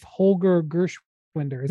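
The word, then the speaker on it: Holger Geschwindner: And he spent some time last year with Holger Gershwinder. Did I say that correctly, Jonathan? – Yeah. Yeah.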